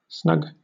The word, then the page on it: snug: British English